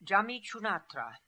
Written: Djameechoonatra

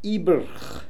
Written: Ibrkh

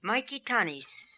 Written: Maikitanis

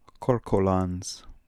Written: Korkolans

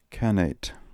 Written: khanate

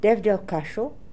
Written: Devdel Kascho

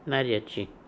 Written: Naria chi

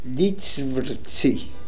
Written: Litsvrtsi

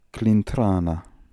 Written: Klintrana

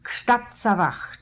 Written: Kshtatsavacht

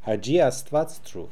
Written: Asvatz Troov Hadji

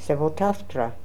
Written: Sevotaftra

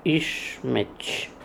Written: Ischmetch

